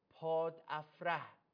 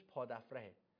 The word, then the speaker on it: پادافره: در فرگردهای پیشین بارها دیدیم که پاد افره، تلفظ درستش پاد افره، من اینجا پادافره هم میگفتم اینجا اشتباه، خدا خیرتون بده عزیزان زرتشتی، موبدان بزرگ و فاضل، یکی زنگ میزد به من میگفتش که آقا این پاد افره تلفظ باید بشود، این عبارت پاد افره گناه دیگه، زنگ میزدید سوال هم اگه جواب نمیده حداقل اینو به ما میگفتید دیگه.